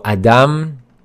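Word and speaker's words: Adam (אדם): So the first word, Adam, as you can see, the first letter is Aleph, and the sound that it makes is Ah. So Adam, that's how it's pronounced.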